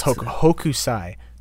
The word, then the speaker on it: Hokusai: Miss Hosokai? – Yeah, Miss Hokusai. The K comes before the S.